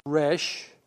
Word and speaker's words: Resh: It's pronounced Resh, represented by this 20th letter.